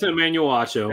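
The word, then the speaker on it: Emmanuel Acho: So, all right, basically, Emmanuel Ocho. How do you say this guy's name? – Yeah, it's Emmanuel Ocho.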